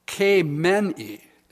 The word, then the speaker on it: Khamenei: Ayatollah Khamenei, is the way it's pronounced, died 5 hours ago.